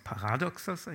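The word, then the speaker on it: Paradoxos (παράδοξος): Aus dem Griechischen kommt das, Paradoxos, Paradoxos eigentlich richtig ausgesprochen.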